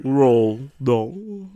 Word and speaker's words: Roald Dahl: Roll Dahl.